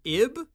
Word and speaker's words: Ib: And at least I think it's pronounced Ib. The title is the letter I and the letter B.